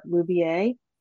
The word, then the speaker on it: Loubier: Loubier.